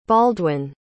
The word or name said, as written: baldwin